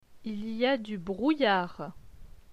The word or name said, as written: Il y a du brouillard